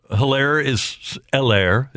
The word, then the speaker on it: Hilaire: Yeah, and Hilaire. The H is silent, but Hilaire is really their only running back.